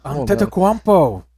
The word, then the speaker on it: Antetokounmpo: The Bucks with Giannis Antetokounmpo. Say that. – Giannis Antetokounmpo. – Antetokounmpo.